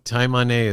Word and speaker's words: Taimane: So, Ty Monet is spelled TaiMane.